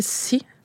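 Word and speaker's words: Assi: Puis Assis, c'est le mot, ça s'écrit A-S-S-I, c'est le mot pour terre dans la langue Innu-Imun. Mais chez moi, dans le dialecte, on va le prononcer Si. Fait que c'est vraiment comme le mot en anglais pour la mer,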